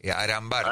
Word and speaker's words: Arambarri: Con una R, Arambarri. – Arambarri, como le gusta a usted. – No, no, es como se dice.